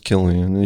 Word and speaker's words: Cillian: You got Cillian Murphy, of course, and it is pronounced Killian.